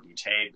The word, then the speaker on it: Boutte: Yes, and then if you want to look for me, I am on Instagram at Phil, P-H-I-L, _ Boutte, B-O-U-T-T-E. If my last name doesn't sound funny, you're not saying it right.